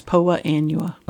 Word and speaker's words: Poa annua: Nutsedge, Poa Anna, is that right? Am I saying that right? Poa Anna? – Oh, annual bluegrass, Poa Annua.